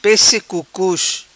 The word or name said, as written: Pesse Cucus